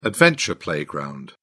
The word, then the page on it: adventure playground: British English